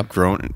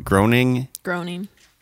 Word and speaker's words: Groening: Groening. – Groening. – It's really hard. It doesn't look how it's spelled.